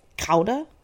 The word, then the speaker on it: Gouda: Apparently they're like 8th generation Gouda cheesemakers, which if you didn't know this, the correct way to pronounce Gouda is not Gouda. That's like an American thing. It's Gouda.